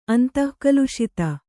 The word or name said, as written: ಅಂತಃಕಲುಷಿತ